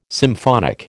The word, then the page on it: symphonic: American English